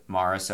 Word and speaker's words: Maurice: If you're wondering, it's M-A-U R-I-C-E, like Maurice. If you saw that name, you'd probably say it Maurice or Morris, like Morris the cat. I think Morris spelled M-O-R-R-I-S. Nope, it was spelled like Maurice, but the man always said it Morris, and how one says his or her name is the way it is said.